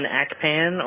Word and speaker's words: Akpan: My name is Sikon Akpan, or at least that's how I pronounce it. It's spelled N-S-I-K-A-N. Last name A-K-P, like in Paul, A-N.